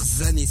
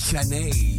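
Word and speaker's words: Zhané: Faut pas dire Zané, ça s'écrit Z-H-A-N-E, faut dire Janey.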